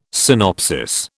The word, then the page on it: synopsis: British English